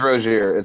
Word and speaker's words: Rozier: Wait, you haven't heard of the untouchable Terry Rozier? Am I pronouncing that right? I don't care to know his name. Rozier. It's Rozier.